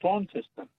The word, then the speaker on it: Launceston: I mean, I was told when I was in Launceston that you've got to hit it's Launceston, not Launceston. Is that correct? – Lawn, yeah, got to say lawn. There's no lawn there, it's Launceston.